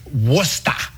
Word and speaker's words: Worcester: It's pronounced Worcester.